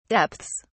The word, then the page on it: depths: British English